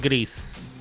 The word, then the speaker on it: Grease: En España se llama grease y lo pronunciamos gris.